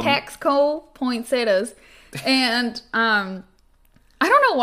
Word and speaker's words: Taxco: You Google, it's spelled Taxco, you guys. – Like taxes, then co. – Yeah, Taxco, Mexico.